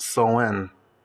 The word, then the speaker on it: Samhain: So, Samhain is pronounced so in.